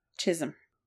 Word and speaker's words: Chisholm: It includes a farm and outbuildings, and they are all on 23 acres of land off the Chisholm Trail. Am I saying that right? – Chisholm. Yes.